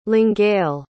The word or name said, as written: longueuil